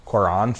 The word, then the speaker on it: Cuarón: Cuarón for Alfonso Roma.